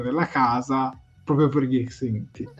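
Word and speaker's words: Xindi: No, esattamente, non è un caetiano, ma, ebbene, è un Xiniti, che è stato visto... – Non si pronuncia così. – Come si pronuncia? Correggimi, te ne prego, non lo so dire. – Si pronuncia tipo Xinti, qualcosa del genere.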